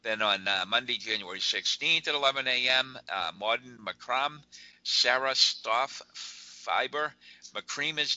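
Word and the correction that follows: macramé: In this class, you will learn basic macrame. I mispronounced that. I apologize. – That's okay. You figured it out.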